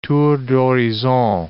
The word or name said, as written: tour d'horizon